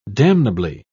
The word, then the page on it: damnably: British English